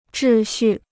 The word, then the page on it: 秩序: zhì xù